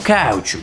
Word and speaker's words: каучук: Я бы даже сказал каучук. – Каучук. – Нет, на самом деле каучук. Это как говорить правильно? Каучук.